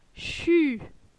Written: Chu

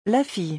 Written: La fille

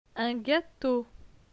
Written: Un gateau

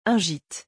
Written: Un gite

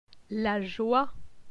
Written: La joie

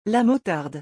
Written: la motarde

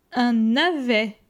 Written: Un navet

